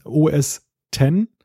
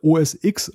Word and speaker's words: OS X: Ich sage immer OS X und es ist völlig richtig, weil er schreibt OS X, das X steht eben für römisch 10 und das heißt OS 10, weil es ja als die Nachfolge von Mac OS 9 damals war.